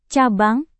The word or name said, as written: cabang